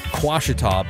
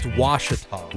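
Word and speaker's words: Ouachita: And if you type it in, it does say for that redneck news story that the parish it comes from was Quashita, but apparently it's pronounced Washita. – Washita. What does it start with? A Q? – No, it starts with an O,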